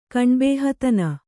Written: ಕಣ್ಬೇಹತನ

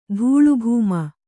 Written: ಧೂಳುಭೂಮ

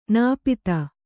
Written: ನಾಪಿತ

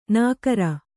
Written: ನಾಕರ

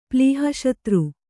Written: ಪ್ಲೀಹಶತ್ರು

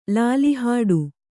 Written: ಲಾಲಿಹಾಡು

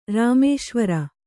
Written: ರಾಮೇಶ್ವರ

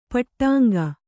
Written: ಪಟ್ಟಂಗ

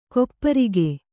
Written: ಗೋಪರಾಗ

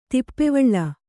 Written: ದಪ್ಪವಳಿ

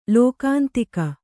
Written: ಲೋಕಾಂತಿಕ

